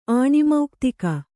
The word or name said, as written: ಆಣಿಮೌಕ್ತಿಕ